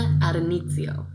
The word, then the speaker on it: Addonizio: By Kim Adonizio. Is that how you say it? Yeah, yeah.